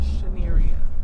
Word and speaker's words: Chanearia: My name is Chanearia.